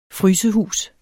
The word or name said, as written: frysehus